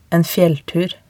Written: en fjelltur

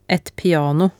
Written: et piano

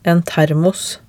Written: en termos

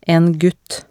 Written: en gutt

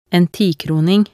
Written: en tikroning

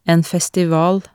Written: en festival

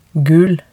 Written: gul

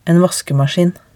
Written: en vaskemaskin

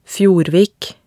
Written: Fjordvik

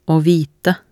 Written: å vite

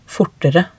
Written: fortere

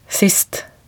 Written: sist

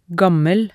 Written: gammel

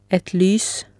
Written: et lys

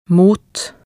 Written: mot

